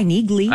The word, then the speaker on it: Neagley: It's the 4-season finale of Reacher tonight on Amazon, but then also the series premiere of the Reacher spinoff, Negley, on Amazon. – What? – I think that's how you pronounce it. N-E-A-G-L-E-Y, Negley.